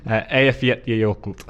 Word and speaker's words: Eyjafjallajökull: It's something along the lines of, and I'm going to apologise for any Icelandics listening, Eyjafjallajökull.